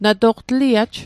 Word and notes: na to'q: Mi'gmaq

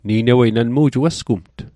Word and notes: ni'newei: Mi'gmaq

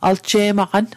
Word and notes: alje'maqan: Mi'gmaq

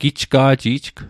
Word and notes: gijga'jijg: Mi'gmaq

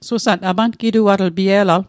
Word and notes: apangituatl: apankituatl

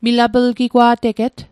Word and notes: milaplgigwa'teget: Mi'gmaq

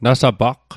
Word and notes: nasapaqq: Mi'gmaq

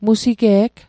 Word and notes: musige'g: Mi'gmaq

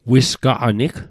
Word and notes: wisgaqanig: Mi'gmaq

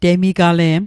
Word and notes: temigale'm: Mi'gmaq

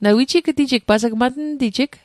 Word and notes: matntijig: matn'tijik

